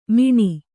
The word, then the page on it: ಮಣಿ: maṇi